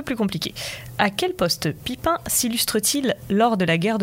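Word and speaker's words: Pippin: donc j'espère qu'on dit bien Pipin. – Oui. Pipin, ouais, voilà, c'est ça, il n'y a pas de prononciation à l'anglaise.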